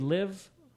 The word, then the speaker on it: Liv: Would you pronounce L-I-V, Liv? – Yes. – Liv. – Okay. Like Livia. Olivia, probably. – No, it's just Liv.